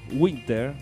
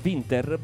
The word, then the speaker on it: Winter: Poi questo tuo cognome, che in molti credo pronuncino Winter, ma invece si dice Winter, perché credo che tu abbia origini nord europee, non so se mi sbaglio, centroeuropee, qualcosa.